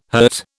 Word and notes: hurt: British English

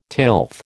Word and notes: tilth: American English

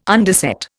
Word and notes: underset: Indian

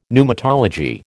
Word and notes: pneumatology: American English

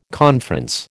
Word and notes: conference: American English

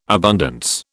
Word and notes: abundance: British English

